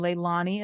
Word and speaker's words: Leilani: And Leilani is spelled LeilaniM is for my middle name, last name Wells.